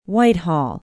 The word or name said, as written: Whitehall